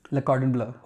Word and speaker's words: Le Cordon Bleu: Le Cordon Bleu.